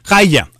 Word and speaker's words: Hiya: Haya, hay una aplicación que se llama Haya, se escribe H-I-Y-A.